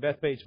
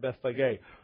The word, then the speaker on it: Bethphage: Because in it we notice first in verse number 1 of chapter 21, when they drew nigh unto Jerusalem, they came to Bethphage. I grew up saying Bethphage, but it's pronounced Bethphage.